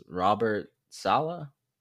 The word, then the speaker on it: Robert Saleh: I think their new head coach is Robert Saleh.